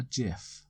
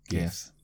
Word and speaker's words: GIF: Do you pronounce it GIF or JIF? GIF. I'm dyslexic, so it has to be GIF. – Yeah. I know that the guy that invented GIFs said they were to be pronounced JIF, but then it should have started with a J, in my opinion. So it's a GIF.